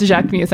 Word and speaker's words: Jacquemus: It's Jacquemus. I know it's pronounced Jacquemus.